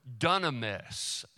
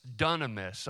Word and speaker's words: dunamis: So the word power, it is in the same word family as the word dunamis, dunamis. And let me spell that for you, dunamis, D-U-N-A-M-I-S.